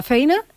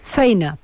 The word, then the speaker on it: feina: Pues lo primero, ser consciente de esto, de la feina i de la escola. ¿Lo he dicho bien? – Feina, quasi. – Feina. – Feina, molt bé.